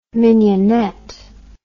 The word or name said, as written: minionette